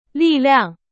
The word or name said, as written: 力量